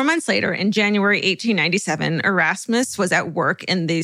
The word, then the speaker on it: Erasmus: when she met a man named Ursmus Stribling Trout Shoe. – No. – Yes. – No. – Erase, his first name is spelled E-R-A-S-M-U-S. – Erasmus.